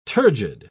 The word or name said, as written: turgid